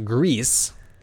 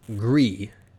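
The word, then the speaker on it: Gris: they tweeted back to him that they said it's pronounced Grease, like Grease Monkey. – Right? Yeah. – But technically is... Gree, because it means gray in, I believe, Spanish.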